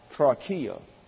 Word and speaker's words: trochia: T-R-O-C-H-I-A, pronounced trachea. And trachea in the Greek is the Greek equivalent of the Hebrew word magal. M-A-G-A-L, magal.